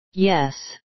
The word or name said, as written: yes